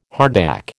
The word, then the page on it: hardback: American English